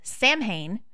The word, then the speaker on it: Samhain: It's spelled S-A-M-H-A-I-N, and therefore I say it in my head as Samhain, but that's not right. It's Samhain.